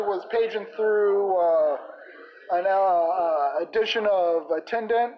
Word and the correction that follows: Attentat: or whatever it's called, A-T-T, Attentat, yeah, that's what it is, Attentat, You see, that's what I'm talking about right there.